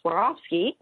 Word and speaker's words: Swarovski: But then, boom, the I'm gonna say it properly again, Swarovski.